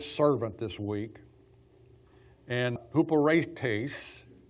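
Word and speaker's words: huperetes (ὑπηρέτης): So the word serve here is, if you translate literally, so you have H-Y-P-E-R-E-T-E-S is how it's spelled. It's pronounced huperetes.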